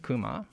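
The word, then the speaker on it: Kuma (熊): The bear is Ur, because the bear in Japanese pronounced Kuma, but he couldn't say Kuma.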